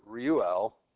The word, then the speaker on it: Reuel: Jethro and Reuel. I never said that right. Reuel.